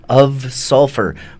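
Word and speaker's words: Ov Sulfur: The band is Of Sulfur, but it's spelled like O-V Sulfur.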